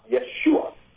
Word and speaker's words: Yeshua: His name in Hebrew is pronounced Yeshua.